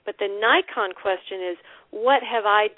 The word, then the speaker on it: Naikan: It really flips your perspective, and that's based on a really interesting Japanese psychology technique that's called Nikon, which is it's pronounced Nikon like the camera, but it's spelled Naikan.